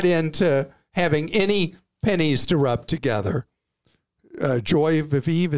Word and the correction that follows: joie de vivre: is how you say it. Joie de vivre. Joie de vivre. Thank you.